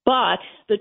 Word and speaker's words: Bout: But the trade was for a guy named Viktor Anatolievich Bout. It looks like B-O-U-T, but in Russian, it's pronounced Butt.